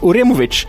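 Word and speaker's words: Uremović: Mm, de har plockat in kroatiska landslagsbacken Filip Oremov... bra uttal. Filip Oremovic.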